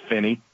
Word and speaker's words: Finney: I think it's Finney. That's how you say it.